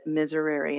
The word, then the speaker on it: miserere: Well, I Googled it, and I said, how do you pronounce this word? I did that today. The guy on Google said miserere.